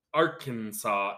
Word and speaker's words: Arkansas: And also, have you ever seen that Vine where the one lady's trying to learn English and she's like, why is this Arkansas and this is Kansas? Why is it not Arkansas? Arkansas, because it's spelled Kansas.